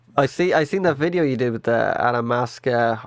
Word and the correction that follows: Amanita muscaria: I'm going to say it wrong. The fucking mushroom. The red and white one. – Oh, Amanita muscaria.